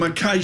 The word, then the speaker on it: Mackay: Am I pronouncing that right? – Got it. – Mackay, I nailed it. Mackay yes. Shields.